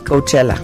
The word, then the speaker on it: Coachella: ¿Cómo se dice? – Coachella. – Coachella.